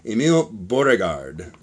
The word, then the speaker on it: Emil Beauregard: We'd like to give a quick shout out to Emil, let's see if I'm pronouncing this right, Emil Beauregard. – You nailed it. – I think that's right. – 100% Emil, correct.